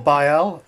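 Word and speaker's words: Baille: Cyril Bayl. It's like Braille, but without the R.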